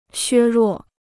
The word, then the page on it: 削弱: xuē ruò